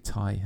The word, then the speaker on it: Tighe: Ty is spelled T-I-G-H-E. That's got to be Ty, hasn't it? It's not Tig.